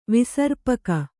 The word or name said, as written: ವಿಸರ್ಪಕ